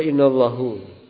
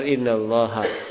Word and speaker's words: innallaha: Kok dibaca innallahu? Nah. Salah. Benar innallaha.